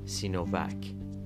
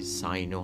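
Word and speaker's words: Sino: سینو رو که در انگلیسی ساینو هم تلفظ میشه ساینو یا سینو به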